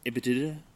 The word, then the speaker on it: EBITDA: EBITDA. – EBITDA. That's how you say it.